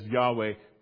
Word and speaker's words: Yahweh: As we look at the scripture this morning, it would be correct to say and to pronounce the name where you see it as Lord in the text, as Yahweh, time and again.